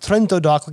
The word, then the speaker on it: Trento DOC: It's Trento DOC, but it's pronounced Trento DOC, like it's just one word.